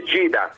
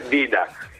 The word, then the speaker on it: Dida: Le D vicino alle I si pronunciano G, quindi è Gida, non è Dida.